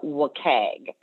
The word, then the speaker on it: WCAG: But often institutions are going to hear about WCAG, and that is how it's pronounced, W-C-A-G.